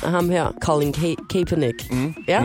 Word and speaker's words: Kaepernick: Kaepernick. – Kaepernick, ja.